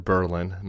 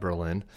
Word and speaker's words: Berlin: Last night in New Hampshire, Berlin, New Hampshire, they pronounce it Berlin, not Berlin.